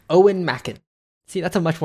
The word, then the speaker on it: Eoin: Oh, that's uh, pronounced Owen, by the way.